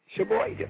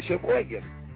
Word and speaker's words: Sheboygan: Sheboygan. It's the only right way to say it. Sheboygan.